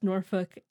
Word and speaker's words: Norfolk: True Grimlock said, "Though I have nothing to contribute to the film or book discussion, as I have not heard of either, I can confirm you pronounced Norfolk as it is said.